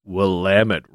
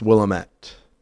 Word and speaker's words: Willamette: Which was probably in arcades by the Willamette River. – Oh, yeah. It's wrong. It's Willamette.